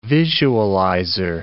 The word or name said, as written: visualizer